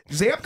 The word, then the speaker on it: Zappe: And you'll probably be able to pronounce, what is it, Bailey Zapp or Zappe, or I think it's Zapp. I don't know. – It is Zapp.